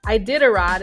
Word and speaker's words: Iditarod: Iditarod is how you say that. It's a weird word.